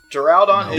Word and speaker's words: Duraludon: Duraludon was the Duraludon is Duraludon. Dragon steel. – Duraludon was the... – Oh, Duraludon. That does sound like a more likely pronunciation.